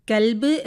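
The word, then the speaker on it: കൽബ് (كلب): കൽബ് എന്ന് പറയുമ്പോൾ നായ എന്നർത്ഥം വരുന്നു. എന്നാൽ കൊൽബ് എന്ന് വരുമ്പോൾ അതിന് ഹൃദയം എന്നാണ് അർത്ഥം വരുന്നത്.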